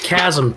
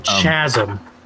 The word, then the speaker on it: chasm: Also, you pronounced chasm chasm.